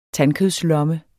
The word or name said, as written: tandkødslomme